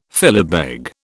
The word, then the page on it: filibeg: British English